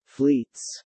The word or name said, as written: fleets